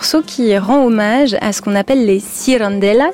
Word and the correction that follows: cirandeiras: si je prononce convenablement. Qu'est-ce que c'est les cirandelas ? – As cirandeiras. – Cirandeiras.